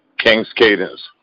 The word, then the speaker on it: King's Cadence: King's Cadence. Yeah, you know, like King and, you know, like Cadence, like, you know, the cadence of the music, but with a K, King's Cadence.